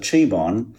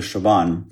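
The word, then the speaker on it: Chabon: Da habe ich auch zum ersten Mal verstanden, als das Hologramm den Namen von Jaban falsch ausgesprochen hat, irgendwie Mr. Cheborn, äh, habe ich erstmal verstanden, dass das eine Anspielung, ein Easter Egg ist, dass der so heißt, weil der Showrunner heißt ja Michael Jaban.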